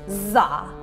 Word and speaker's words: DZA: And that seed syllable is ZA, and it's spelled D-Z-A, pronounced ZA.